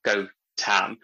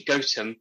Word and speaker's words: Gautam: So my name is Gautam. People pronounce it in different ways. So the way it's spelled, G-A-U-T-A-M, often leads people to pronounce it as Gautam, but it's actually Gautam.